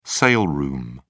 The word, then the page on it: sale room: British English